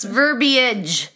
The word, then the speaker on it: verbiage: It's verbiage. Verbiage. Three syllables.